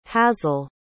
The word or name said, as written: hazyl